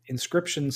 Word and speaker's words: Inscryption: Inscription spelled with a Y, like crypt in the middle.